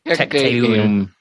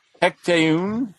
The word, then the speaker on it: Tecteun: Uh, is it Tecturn? Is that how we're doing pronouncing this? – Tecteun. – Tecteun. – Tecteun. Right? Mm-hmm. Yes.